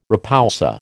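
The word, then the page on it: repoussé: American English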